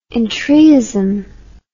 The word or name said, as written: entryism